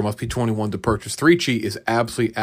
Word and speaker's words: 3Chi: That's right, 3Chi, number 3, Chi, it rhymes.